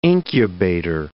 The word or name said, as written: incubator